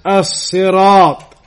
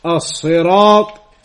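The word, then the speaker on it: As-Sirat (الصراط): In Arabic, it is known as As-Sirat. As-Sirat. And linguistically, it is possible to pronounce the word with three different pronunciations. With a sod, As-Sirat, with a sin, As-Sirat, and with a zai, As-Sirat, linguistically speaking.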